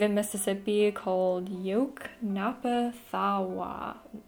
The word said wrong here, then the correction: Yoknapatawpha: Thawa? I can't pronounce it. Yoknapatawpha County.